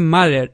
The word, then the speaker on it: Matter: es que no, la pronunciación es Matter, Mat, es que no me sale, o sea, más relajado la T, pero uff, si me viera mi profesora de inglés me mataba.